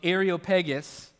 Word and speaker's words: Areopagus: It says, so Paul, standing in the midst of the Areopagus, and yes, that's how you pronounce that word, I practiced many times, said, men of Athens, I perceive that in every way you are very religious.